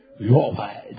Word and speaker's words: Yahweh: There's only one way. I'm telling you how to pronounce it. Yahweh.